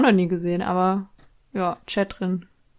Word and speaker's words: Chethrin: auch genannt Chatrin von den ganzen ModeratorInnen bei Promi Big Brother. Aber den schreibt man so ganz komisch, man schreibt ja Catherine C-H-E-T-H-R-I-N. Also ganz merkwürdig.